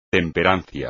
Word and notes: temperancia: espagnol

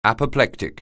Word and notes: apoplectic: anglais

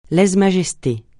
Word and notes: lèse-majesté: francais

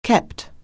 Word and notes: keep: anglais

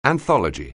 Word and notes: anthology: anglais

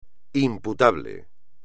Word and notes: imputable: espagnol

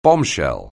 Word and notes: bombshell: anglais

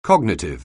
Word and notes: cognitive: anglais

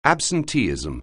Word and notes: absenteeism: anglais

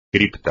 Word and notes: cripta: espagnol

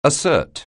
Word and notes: assert: anglais

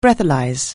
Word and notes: breathalyse: anglais

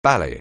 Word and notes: ballet: anglais

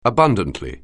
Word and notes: abundantly: anglais

